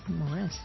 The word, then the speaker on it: Moraes: There's also Pablo Fogundes and Marcos Morares. Moraes. Would you pronounce it like that? – Moraes, yeah.